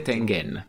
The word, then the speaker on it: Tengen: Dovrebbe essere Tengen. In Giappone, Tengen. Siccome arriva da una parola giapponese, perché è legato al gioco del Go, praticamente, come Atari del resto, Ge, quando leggiamo noi Ge, in giapponese è Ge, Genji, quindi...